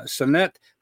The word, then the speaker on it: Sanet: I always say it's sun and it, Sanet. And fun, it's like having fun in the sun.